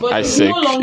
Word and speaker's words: AIESEC: It's a French word, so even if we know the full meaning, we can't exactly pronounce it. – I have difficulty in pronouncing it too, that's why I asked that. Okay, maybe you guys can put out. – But that's the pronunciation. AIESEC is the pronunciation.